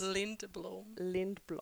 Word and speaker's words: Lindblom: Lindblom. In Swedish.